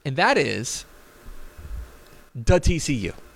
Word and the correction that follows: DATCU: Say it correctly. – DATCU, which is a credit union, mm-hmm.